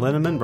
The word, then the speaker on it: Linneman: Val Linneman, Rhymes with Cinnamon,